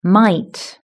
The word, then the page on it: might: British English